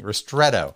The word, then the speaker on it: Ristretto: Oh, is it the Ristretto? – Yes, that's how you say it. Apparently, Ristretto.